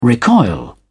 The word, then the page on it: recoil: British English